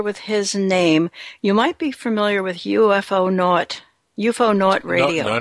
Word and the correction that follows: UFONaut Radio: No, you said it wrong. UFONaut Radio. – UFONaut Radio. – UFONaut Radio. – UFONaut Radio. – It's like Ukraine.